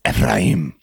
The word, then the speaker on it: Ephraim: If you really go for the pronunciation, it's Ephraim.